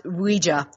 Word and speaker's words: Ouija: And it's actually pronounced Ouija. That's how they said to pronounce it in the original uh, patent.